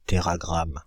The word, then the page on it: téragramme: Paris; [te.ʁa.ɡʁam]; France (Île-de-France); /te.ʁa.ɡʁam/